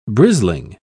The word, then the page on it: brisling: British English